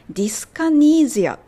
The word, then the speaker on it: dyskinesia: ディスカニーゼアって感じですね。